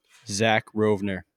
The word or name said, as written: Zach Rovner